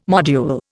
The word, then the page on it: module: Indian